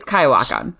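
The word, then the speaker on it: Coyoacán: in Cuyahuacan, Mexico. Is that how you say it, Sarah? – I don't know. Even when we went to that area, no one knew how to pronounce it. – It's Cuyahuacan.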